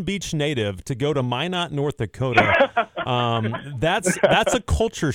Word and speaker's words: Minot: And I can tell you know it because you said Minot correctly. Not a lot of people say that correctly.